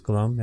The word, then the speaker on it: Gloam: It's Gloam.